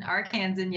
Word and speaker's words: Arkansan: So I'm wondering if you can call up your friend Jerry Jones, your fellow Arkansan. Is that how you say it? – Arkansan, yes. – maybe call up Jerry Jones,